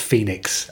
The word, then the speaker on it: Fenix: It's spelled Fenix, but it's pronounced Phoenix.